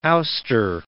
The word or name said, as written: ouster